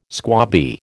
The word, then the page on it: squabby: American English